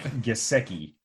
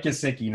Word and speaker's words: Gesicki: It's Mike Gesicki. – Maybe that's why I'm mispronouncing everything, is I just have it spelled wrong every time. There's no spell check. I don't spell check. – Yeah, but Mike Gesicki, number 11.